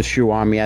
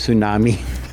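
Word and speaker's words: Tsushima: I butchered that when I said it. It is Ghost of Tsunami. I said Ghost of Tsunami.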